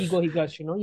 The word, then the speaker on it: Keigo Higashino: Kigo Higashino, yes.